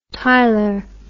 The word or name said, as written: tylor